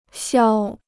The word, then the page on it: 消: xiāo